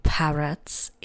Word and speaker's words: parrot: You can see two parrots in the picture now. The word parrot rhymes with the word carrot.